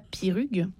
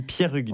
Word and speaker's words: Pierrugue: Parce qu'il y a un E, donc pour moi, on dit Thierry, comme par exemple, on ne dit pas Pirugue, il me semble. – On ne dit pas Pirugue, on dit Pierrugue.